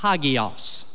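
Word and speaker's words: hagios (ἅγιος): The word you see probably more often in the New Testament is a word that's pronounced something like this, hagios.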